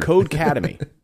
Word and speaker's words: Codecademy: It's Codecademy. – Codecademy. Sorry. Yeah. I keep saying it is. It's hard. – Codecademy.